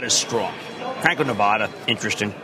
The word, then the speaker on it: Nevada: They've got the lowest cost, and they're also in Nevada. See, I learned how to pronounce that over the weekend.